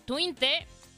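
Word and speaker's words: Twente: campeón de Países Bajos con el Twinte, Sí, sí se pronuncia así, pues.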